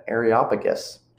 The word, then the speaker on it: Areopagus: This is Paul again, and he's preaching to the Areopagus, that's how you say it.